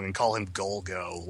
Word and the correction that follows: Gogol: like he's Golgo 13. – I think it's – yeah, it's like they say Golgo, but it's like goggles or something. – It's Gogol, I think, like G-O-G-O-L.